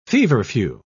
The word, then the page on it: feverfew: British English